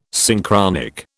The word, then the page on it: Synchronic: British English